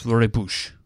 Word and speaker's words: rooibos: And there are two different ways to pronounce the tea, which is spelt R-O-O-I-B-O-S. There is an English way and a Dutch way. So I'm going to do my best here. – Okay. – The English way is rooibos, and the Dutch way is rooibos.